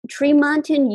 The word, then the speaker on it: Tremonton: Okay, we're going to talk to Eric in Tremont in Utah.